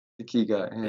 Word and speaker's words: ikigai: I've also watched a uh, YouTube video of yours where you talk about, I'm going to absolutely butcher this, I- Ikigai. – Ikigai.